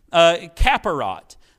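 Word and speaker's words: kapporet: K-A-P-P-O, with a little thing above the O, R-E-T, and I think it's pronounced Kaparot.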